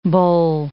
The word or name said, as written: bole